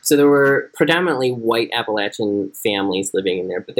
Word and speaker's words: Appalachian: It was primarily white Appalachian. Sorry, let me say that again because I think I botched Appalachian. – No, well, you didn't. You said it right.